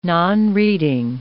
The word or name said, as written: nonreading